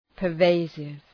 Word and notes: pervasive: pər’veısıv

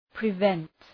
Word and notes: prevent: prı’vent

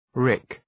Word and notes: rick: rık

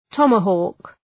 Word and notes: tomahawk: ‘tɒmə,hɔ:k

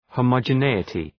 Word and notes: homogeneity: ,həʋmədʒə’ni:ətı

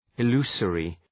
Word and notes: illusory: ı’lu:sərı

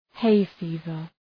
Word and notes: hay fever: heı’fi:vər

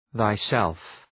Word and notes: thyself: ðaı’self

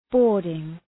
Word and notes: boarding: ‘bɔ:rdıŋ

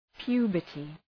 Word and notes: puberty: {'pju:bərtı}

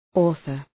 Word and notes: author: ‘ɔ:ɵər

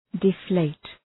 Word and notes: deflate: dı’fleıt

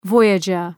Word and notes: voyager: ‘vɔııdʒər

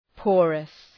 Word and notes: porous: ‘pɔ:rəs

